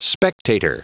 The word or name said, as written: spectator